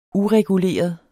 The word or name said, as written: ureguleret